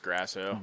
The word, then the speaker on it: Grasso: It's Grasso.